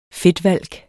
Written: fedtvalk

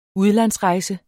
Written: UDLANDSREJSER

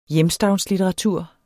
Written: hjemstavnslitteratur

